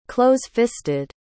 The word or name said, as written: close-fisted